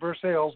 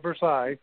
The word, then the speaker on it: Versailles: I ended up giving the guy that ended up taking me back and forth from Versailles. They pronounce it Versailles, not Versailles.